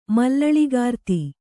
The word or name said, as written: ಮಲ್ಲಳಿಗಾರ್ತಿ